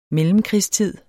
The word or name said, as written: mellemkrigstid